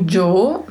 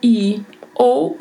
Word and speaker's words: yo: A letra I grega, vindo aí antes de uma vogal, ela tem uma mudança na sua sonoridade. Ela pode ter um som de J, um som de DJ, um som de I ou um leve som de X. E a pronúncia ficaria Jo, Jo, Io, Show. Estas são as possíveis pronúncias a depender das regiões.